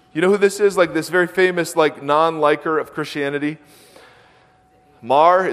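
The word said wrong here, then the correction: Maher: is that how you say it? Mar, Bill M.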